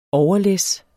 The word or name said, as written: overlæs